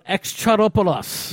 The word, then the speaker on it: Exarchopoulos: Adèle Exarchopoulos.